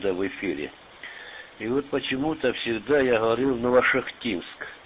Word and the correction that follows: Новошахтинск: Но вот у меня тут есть товарищ такой грамотный. И он подсказал мне, что надо ударение делать на А. Новошахтинск. Ну вот я с тех пор и говорю, что Новошахтинск.